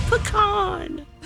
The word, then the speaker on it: pecan: And that's what you get with the pecan. – Jason. Pecan. That's how you say it.